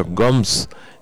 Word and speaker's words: Gumbs: que batió al guardameta Gums, que se escribe G-U-M-B-S, que había atajado bastante, y ahí fue cruzado por el número 18.